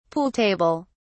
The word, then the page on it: pool table: British English